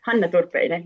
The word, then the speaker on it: Hanna Turpinen: Yes, Hanna Turpinen.